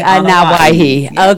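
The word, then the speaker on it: Anawahi (Anoaʻi): For a good rule of thumb, I always say, if you can say Hawaii, you can say Anawahi.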